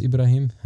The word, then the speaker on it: Ibraheem: My name is Ibraheem.